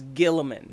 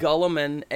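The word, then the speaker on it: Guilliman: Everyone I know says Gilliman. Mm-hmm. They make the U is silent, no, the I is silent. It's Gulliman, and it's Roboot.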